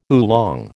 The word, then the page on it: oolong: American English